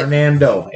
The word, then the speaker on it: Hernando: Did I say that right? – Maybe. Hernandez? Hernando or there's Hernandez? What? – Hernando. H-E-R-N-A-N-D-O. – Yeah, you said it right. – O-U-S-E.